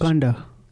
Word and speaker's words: Kanda: And of course, the fact that Priyanka Chaturvedi, and I think it's very good she's done that, has spoken against, what is his name, Kanda or Konda? The Haryana alleged rapist. – Kanda. – Kanda.